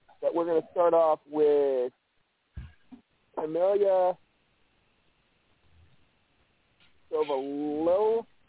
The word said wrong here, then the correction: Saviola: I think it's Saviola. Yeah, Camille Saviola. – Yeah, I think you're right.